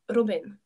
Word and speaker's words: Robin: you'd pronounce it like Robin. – Robin. – You Robin. roll your R not too long, like just like touch the R and then okay.